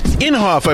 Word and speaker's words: Inhofe: Inhofe, I believe, is how it's said.